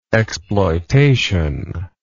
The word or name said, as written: exploitationally